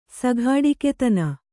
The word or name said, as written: ಸಘಾಡಿಕೆತನ